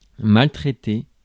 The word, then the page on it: maltraiter: [mal.tʁe.te]; /mal.tʁɛ.te/